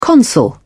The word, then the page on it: consul: British English